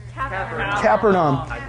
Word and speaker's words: Capernaum: And he does this really, really awesome thing in this place called Capernaum. You guys say that? – Capernaum. – And you can pronounce it like I've heard it a thousand different ways, but that's how I like to say it.